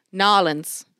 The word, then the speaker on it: New Orleans: Nolens. It's pronounced Nolens. You uncultured swine.